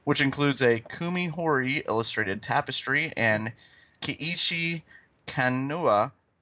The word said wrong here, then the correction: Kano: I pronounced that one right. – I think you got the first part right. The second part, I believe, is Kano, okay.